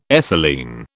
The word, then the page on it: ethylene: British English